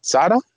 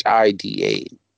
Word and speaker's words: Shida: Of course with Big Swole and is it Sada? It's Shida.